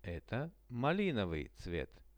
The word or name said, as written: eto malinovyj